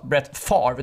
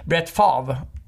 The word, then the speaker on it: Brett Favre: För han, det ska alltså uttalas Brett Favre, det stavas Favre egentligen. Så han säger Brett Favre.